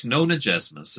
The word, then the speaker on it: Nonagesimus: When I heard Nonagesimus, I thought it was two words. It's spelled Nonagesimus. I thought it was like Nona space Jesimus, like J-E-S, etc. I was incorrect. Harrow Hawk Nonagesimus is how Moira Quirk, the audiobook narrator, always says it.